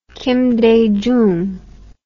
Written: kim dae jung

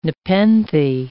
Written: nepenthe

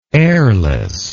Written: airlessness

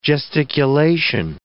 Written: gesticulation

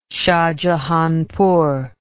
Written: shahjahanpur

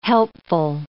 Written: helpful

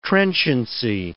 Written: trenchancy

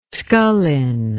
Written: Scullin

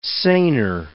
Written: seiner

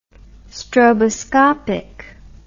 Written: stroboscopic

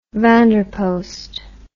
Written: van der post

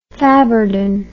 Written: faburden

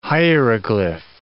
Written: hieroglyph

